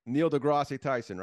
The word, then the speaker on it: Neil deGrasse Tyson: Like, so like you read books, like stuff on what Neil deGrasse Tyson, right?